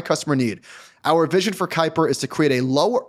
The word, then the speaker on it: Kuiper: It's K-U-I-P-E-R. Kuiper? – Kuiper.